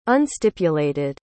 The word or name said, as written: unstipulated